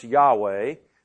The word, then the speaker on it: Yahweh: Yahweh, in Hebrew, it would be pronounced Yahweh Mikdash. In English, we would use the J sound and call God Jehovah Mikdash.